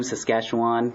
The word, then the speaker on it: Saskatchewan: Thank you, Saskatchewan.